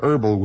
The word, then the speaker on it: herbal: But it is distinctly burnt, and it is a distinct herbal smell, herbal with a silent H, as though something has burned that is highly aromatic.